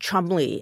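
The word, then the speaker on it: Cholmondeley: I think it's pronounced Chumley.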